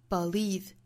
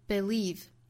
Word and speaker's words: believe: 首先需要注意第一个词就是 believe 这个词，我们看它第一个音节是可以读 schwa sound, a believe, 也可以读成一个 i 这样的元音，就是 sit 里面那个 i, 那可以读 believe. 也可以读 believe, 所以这个词它其实是有两种发音的。